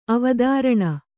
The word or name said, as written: ಅವಧಾರಣೆ